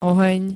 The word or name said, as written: ohen